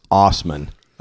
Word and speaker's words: Asman: For the record, Asman always pronounced his own name as Osman. – Osman. – But it is literally spelled A-S-S man. Yeah, it's definitely not Osman.